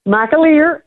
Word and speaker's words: McAleer: Am I pronouncing your husband's name correctly? – McAleer. No, it sounded good.